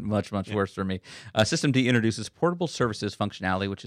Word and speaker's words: systemd: Systemd, very good.